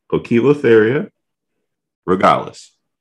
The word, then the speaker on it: Poecilotheria regalis: Poecilotheria regalis.